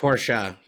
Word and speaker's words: Porsche: It's Porsche.